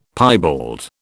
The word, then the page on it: piebald: British English